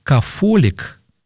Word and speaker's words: кафолик: слово «кафолик», не «т», а «фео», ну, в разных языках по-разному это читается, так как не русское слово, поэтому кафолической церковью было раньше, если уж тут вот кто-то, кто там был раньше.